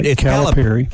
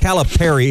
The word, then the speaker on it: Calipari: Calipari. Calipari is the correct pronunciation. – I've been saying it right and then saying it wrong. – I've been alternating. – It's like the band Perry.